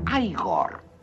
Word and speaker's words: Igor: No, se pronuncia Igor. – A mí me dijeron que era Igor.